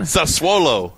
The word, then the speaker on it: Sassuolo: How do you say that? – Sassuolo.